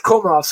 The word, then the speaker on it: Komarov: It's Komarov, so if you're gonna make fun of him, pronounce his name right.